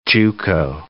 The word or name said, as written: juco